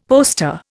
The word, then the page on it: poster: Indian